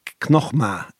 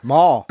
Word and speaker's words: Knockma: Spell it. – Knockmah. K-N-O-C-K-M-A. Knockmah. It is the ancient. – Spell the ma bit of it. – M-A father. – M-A. Maw. – Knockmah. Knockmah. It is the ancient.